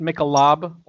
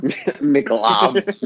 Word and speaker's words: Michelob: Plus, it's pronounced Michelob Ultra. – Michelob. Michelob. It's Michelob.